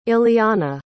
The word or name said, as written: illeana